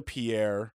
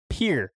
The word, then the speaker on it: pier: Pierre? It's Pierre, you idiot. – I thought it was Pierre. Pierre is a fucking name. – How do you say it? Pierre? – peer. It's a peer.